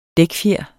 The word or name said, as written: dækfjer